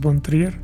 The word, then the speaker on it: Trier: Lars, Lars von Kepier, perdón. Lars von Trier.